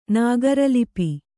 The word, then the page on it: ನಾಗರೀಲಿಪಿ: Kannada